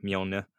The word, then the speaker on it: Mjolnir: They split up to find him, and so Thor is flying across the city, guided by his hammer Mjolnir.